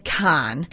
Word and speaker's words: Cannes: But it's C-A-N-N-E-S is called con. – Con. – That is dumb. Con is C-O-N.